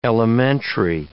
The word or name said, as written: elementary